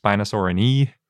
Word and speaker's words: Spinosaurinae: And I just want to point out, I just learned after reading about how to pronounce Latin names that things that end in AE should probably technically be pronounced E and not A, so it should probably be pronounced Spinosaurinae, but that seems really weird.